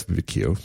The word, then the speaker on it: queue: How do you say that? – That's Q. – Q, thanks. It's spelled really weird. It's spelled like with 8 U's. – It's very difficult word. – Q-U-E-U-E. – Yeah, although it is a single syllable.